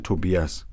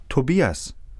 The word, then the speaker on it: Tobias: Ich heiße Tobias. – Wie bitte? – Tobias. – Und wie buchstabiert man das? – T-O-B-I-A-S.